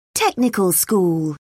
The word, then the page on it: technical school: British English